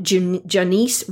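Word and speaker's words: Jonice: check out Janice Webb. It's hard for me to say her name. It's like Janice, but with an O. J-O-N-I-C-E Webb, W-E-B-B.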